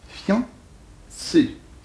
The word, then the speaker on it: fiancé: C'est fiancé, c'est-à-dire deux syllabes. Bon, il ne faut pas prononcer un i, mais on prononce un iode. Fian. Une seule syllabe, voyons.